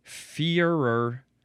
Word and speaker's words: Führer: It's the word Führer or Führer. It is F-U-H-R-E-R with the two dots on the U.